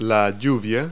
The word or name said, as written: rain